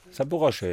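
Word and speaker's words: Saporoshche (Запорожье): Ihr habt Champions League zu Hause bei einer Mannschaft, wo du mir jetzt schon mal sagen kannst, wie ich das im Fernsehen aussprechen soll. Saporoshche. – Saporoshche, ja. – Klingt das gut? – So würde ich es auch sagen.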